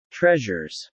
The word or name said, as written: treasures